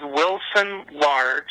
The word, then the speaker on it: Wilson Large: Hello, my name is Wilson Large.